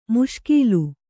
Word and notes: ಮುಷ್ಕೀಲು: Kannada

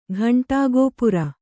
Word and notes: ಘಂಟಾಗೋಪುರ: ghaṇṭāgōpura

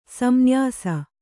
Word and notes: ಸಂನ್ಯಾಸ: samnyāsa/san nyāsa

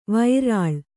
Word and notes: ವಿರಲ: kannada

